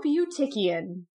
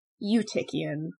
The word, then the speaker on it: Eutychian: I looked up as much as I possibly could, so I, this is the conclusion I have come to. It's Eutychian.